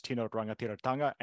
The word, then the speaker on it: Tino Rangatiratanga: And the 29 games in Aotearoa, or New Zealand, they'll feature the national Maori flag, also known as Tino Rangatiratanga, and the New Zealand national flag. Yes, I'm very, very pleased that I managed to pronounce that properly.